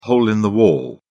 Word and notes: hole-in-the-wall: British English

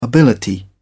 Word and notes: ability: British English

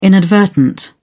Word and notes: inadvertent: British English

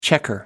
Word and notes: checker: American English

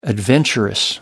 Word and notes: adventurous: American English

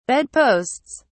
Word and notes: bedposts: British English

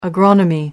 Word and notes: agronomy: American English

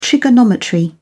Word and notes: trigonometry: British English

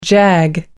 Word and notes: jag: American English

